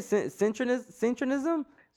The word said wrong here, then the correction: syncretism: Syncretism. – Synchronism. – Syncretism. So synchronicity. The word synchronicity. – Right. – But with tism.